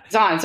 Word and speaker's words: Zahn: He really, Zane McLaren, I'm sorry, did I say his name right? – I think it's Zahn, yeah. – Zahn, sorry.